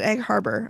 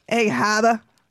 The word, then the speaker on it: Egg Harbor: I was very upset when he pronounced it Egg Harbor. I'm like, come on. – Come on. You know how to say Egg Haba. – Egg Haba.